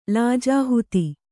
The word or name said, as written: ಲಾಜಾಹುತಿ